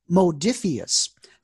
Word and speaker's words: Modiphius: I pronounced the name of the company wrong. It is Modiphius.